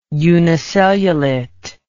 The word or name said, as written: unicellulate